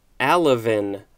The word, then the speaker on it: alevin: Next we have alevin. I think that is the correct pronunciation. A-L-E-V-I-N.